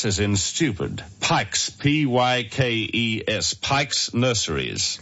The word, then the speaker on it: Pykes: Ring Pike's Nurseries. – What nurseries? – Pike's. – Pike's. – Yes. P-Y-K-E-S. – P-I-A.